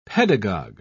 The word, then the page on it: pedagog: British English